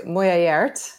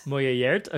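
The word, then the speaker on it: Muylaert: It is written, and you're going to have to help me with these pronunciations, please, when I inevitably get the Portuguese or Brazilian pronunciations wrong. So this is written and directed by Anna. – I have a hard time, too. Mulheriert. – Mulheriert, okay.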